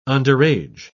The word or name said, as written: underage